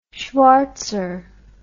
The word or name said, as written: schvartzer